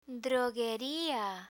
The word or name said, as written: drogueria